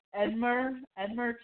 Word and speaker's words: Edmure: I did love when Edmer Tully, is that how I say his name? – Yeah.